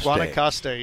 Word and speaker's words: Guanacaste: Guanacaste, yes, sir. And everybody mispronounces it.